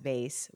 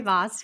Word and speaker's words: vase: What is the right pronunciation? Voss, vase, what? – I don't know. I say vase, but I think plenty of people say voss too. – Voss, I guess, depending on where you're from and how you're going to pronounce it.